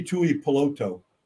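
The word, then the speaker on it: Tuipulotu: But Tui Polotu. You want to know a Polynesian name, just pronounce every vowel.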